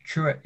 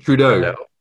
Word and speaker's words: Trudeau: Is it Kim Jong Un, Donald Trump, Emmanuel Macron, or Justin Trudeau? Is it how do you pronounce it? – Trudeau.